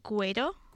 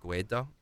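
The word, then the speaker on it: Guaidó: And his name is Juan Guaido. I do not know how to pronounce Spanish names. So did I get that right? – I'm going to say Guaido. – Guaido. Okay. I'll say that.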